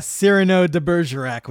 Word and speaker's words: Cyrano de Bergerac: Yeah, a real, yeah, Cyrano de Bergerac we got on our hands.